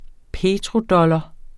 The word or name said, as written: petrodollar